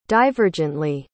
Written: divergently

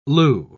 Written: lieu

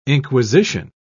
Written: inquisition

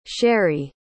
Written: sheri

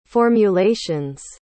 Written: formulations

